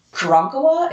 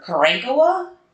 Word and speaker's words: Karankawa: And I want to go ahead and say that Karankawa is how we pronounce it here in Texas. I've heard many people pronounce it that way. I have also heard Karankawa, and I have heard Karankawa. And if you check Google, she says Karankawa.